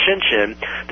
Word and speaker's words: Shenzhen: They can literally have, in fact, I think there's a town, Shenzhen, I can't spell it, it's pronounced Shenzhen, that has, as we speak, 12.4 million people and 100,000 factories in the one town.